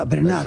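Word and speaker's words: Bernhardt: Tienes que pronunciar a la francesa, Bernard.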